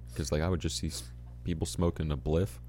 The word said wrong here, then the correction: spliff: It's pronounced spliff. What the fuck is a bliff? – This is my knowledge of the marijuana scene. I have none. – It's a spliff.